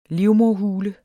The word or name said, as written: livmoderhule